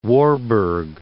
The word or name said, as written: Warburg